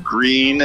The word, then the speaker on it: Gruene: We've been in a little place called Green. It's spelled G-R-U-E-N-E.